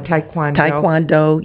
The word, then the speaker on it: Taekwondo: And it was very interesting, too, that the Kwodong, how do you pronounce that? – Taekwondo, yeah.